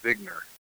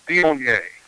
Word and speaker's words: Viognier: If you look at how it's spelled, it looks like Vigner. – Yep. – But it's Viognier.